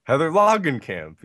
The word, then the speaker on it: Heather Langenkamp: Heather Langenkamp, yes.